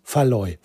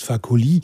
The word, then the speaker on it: Fakoly: Und nicht Faloy. Faloy ist völlig falsch. Was ich immer gesagt habe, warum auch immer, habe ich ihn immer Faloy genannt. Aber er heißt Fakoly.